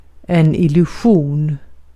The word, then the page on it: illusion: /ɪlɵˈɧuːn/